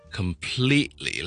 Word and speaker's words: completely: 今日同大家讲个话题系懒得就可以懒嘅音，好常听到咧，人哋讲 completely, c o m p l e t e l y, 完全地啦，OK? Completely 咧，好多人咧将读成咧 completely 咁，听落去咧个 T 音就读得好清楚，好似好正确咁。咁事实上咧，亦都系正确嘅 completely。